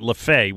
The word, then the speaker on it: Lefebvre: Not only do I remember Joe Lefebvre, but I remember that he spelled his name Lefevre, but pronounced it Lefebvre, which I can't tell you why.